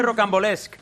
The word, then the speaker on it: Rocambolesque: Directora de Rocambolesque.